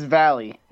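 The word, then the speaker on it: Vallely: who is skateboarder Mike Valley or Valley. What is it here? Mike V. It's Valley. – Yeah. It's Valley. There's an extra E in there.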